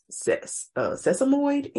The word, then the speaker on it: sesamoid: They said it in addition to the right toe sprain that we knew about, it was also a sesamoid injury, I believe that's how you pronounce it.